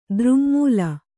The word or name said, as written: ದೃಙ್ಮೂಲ